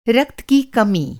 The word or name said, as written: रक्त